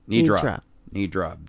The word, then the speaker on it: nidra: Yoga Nidri. Nidra. Banish. – You got it.